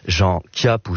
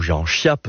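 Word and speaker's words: Chiappe: La veille de ce jour-là, le préfet de police de Paris, Jean Chiap ou Jean Chiap, selon la façon dont on veut le prononcer, est révoqué par le gouvernement d'Edouard Daladier.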